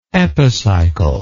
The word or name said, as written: epicyclical